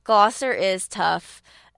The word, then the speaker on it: Gloucester: Gloucester is tough.